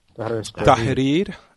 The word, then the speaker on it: Tahrir: Tahrir. – Thank you. – Tahrir. – Tahrir. Get a lesson in pronunciation, Turkey? – You just pronounce it right.